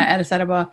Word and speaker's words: Addis Ababa: So we just met a girl who was you know, she came to Addis Ababa, right? – Did I say it right? – Yes. – Yeah, Addis Ababa. Addis Ababa.